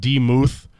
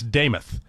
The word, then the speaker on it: Demuth: It's not Demuth or Damuth. It's Damith.